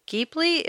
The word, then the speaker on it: Ghibli: It was made by the very popular anime studio, Studio Ghibli, if I'm pronouncing that right. – Sounds right. – Is that right? – Yeah.